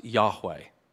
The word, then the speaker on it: Yahweh: Now, in the oldest Hebrew manuscripts, there's consonants and no vowels. But the ancient rabbis and modern scholars tend to agree that it was most likely pronounced Yahweh.